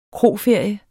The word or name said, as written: kroferie